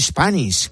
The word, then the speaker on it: Spanish: Bueno, la siguiente palabra es Spanish, que verás tú si es necesaria para nosotros. – Pues tendemos a pronunciarla con una E delante de la S.